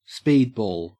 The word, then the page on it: speedball: Australian English